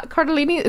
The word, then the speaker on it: Cardellini: Is yeah, that how you say it? Yeah, Cardellini. It sounds like a pasta.